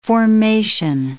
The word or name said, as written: formation